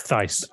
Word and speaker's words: Thijs: But either way, let's actually beautifully segue into the next one from Thijs van der Tween. – Thijs. – I'm sorry. Is it Thijs? Thijs? Like Thijs? – J is a Y.